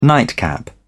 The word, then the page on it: nightcap: British English